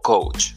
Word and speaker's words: coach: como coach.